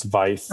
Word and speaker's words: Veith: You actually pronounced my last name wrong. It's Veith. Oh, but that's okay. Veith.